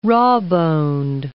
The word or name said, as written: rawboned